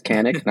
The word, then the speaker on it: Kanik: He still doesn't know how to say my name properly. It's Kanik, not Kanik.